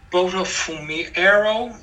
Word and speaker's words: Botafumeiro: Do you want to pronounce that for me? Bota Fumero. It's in the third paragraph in the second box. second to last line. It's muted. – Botafumeiro. – Botafumeiro? Yeah. Okay.